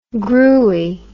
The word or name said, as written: gruey